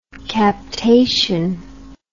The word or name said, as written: captation